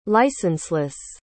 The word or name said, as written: licenseless